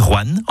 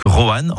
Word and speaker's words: Rouen: Vous avez remarqué comment je dis Rouen en une syllabe plutôt que Rouen en 2 syllabes ? C'est comme ça qu'on y dit dans la région.